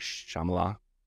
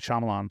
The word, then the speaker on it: Shyamalan: Night Shyamalan. – M. Night Shyamalan. – Shyamalan. – Shyamalan.